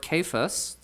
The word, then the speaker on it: Cephas: And the word Cephas, that's actually how it's pronounced, not Cephas, it's pronounced Cephas.